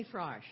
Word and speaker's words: Frosh: Oh, Jenny Frosch. I'm sorry. – Thank you. – F-R-O-S-H.